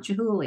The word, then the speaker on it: Chihuly: I thought you were saying with a T, but it's a CH, a hard CH sound. Chihuly.